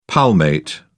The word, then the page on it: palmate: British English